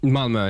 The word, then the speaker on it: Malmö: Malmö, yeah.